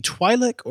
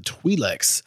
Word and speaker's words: Twi'lek: My number three is the, it depends on how you pronounce it, the Twi'lek or the Twi'lex.